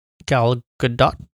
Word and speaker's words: Gal Gadot: Gal Gadot. Is that how you pronounce it? – Yes.